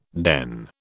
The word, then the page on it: den: British English